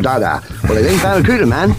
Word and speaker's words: Barracuda: Hey man, the name of the new Plymouth fastback is the Barracuda. I can't pronounce Barracuda. Well listen man, try this. Say ba, ba, ra, ra, coo, coo, da, da.